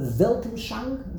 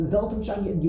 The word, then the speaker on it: Weltanschauung: It's the whole, there's a German word, the Weltanschauung, is that how you pronounce it? – Yes. – The Weltanschauung, your worldview, the Weltanschauung of a person.